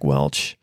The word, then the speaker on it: Welch: I called him Jack Welch as opposed to Jack Welch. I regret the error, not like the country. It's Jack Welch.